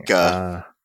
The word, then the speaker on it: Taika: Taika.